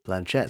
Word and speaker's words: Blanchett: Is that how you pronounce her name? – Blanchett. That's how, yeah.